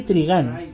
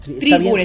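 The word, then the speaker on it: Trigun: La serie Trigán. Eh, Trigún, estamos en Chile y según la regla ortográfica se pronuncia eh, cómo se escribe.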